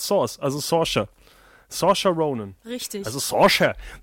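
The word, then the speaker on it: Saoirse: Source Ronan? – Nein, sie heißt Sorsha.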